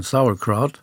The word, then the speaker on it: Sauerkraut: Eigentlich muss man es amerikanisch aussprechen, Sauerkraut.